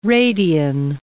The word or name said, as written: radian